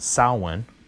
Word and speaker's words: Samhain: And then I remember hearing about his second band called Samhain, which I believe is the proper way to pronounce it. I think it's proper Gaelic pronunciation. And it's spelled S-A-M-H-A-I.